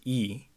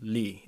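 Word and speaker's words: Lee (이): The last name Lee, in Korean we would say it as E, but in North Korea the same last name is pronounced Lee and written as L-I or L-E-E.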